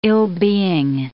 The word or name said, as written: ill-being